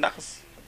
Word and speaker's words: nachat: Because they pronounce taf, saf. So nachat became nachas.